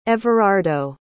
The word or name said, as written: everardo